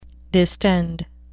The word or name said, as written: distend